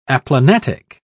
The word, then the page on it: aplanatic: British English